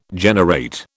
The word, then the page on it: generate: British English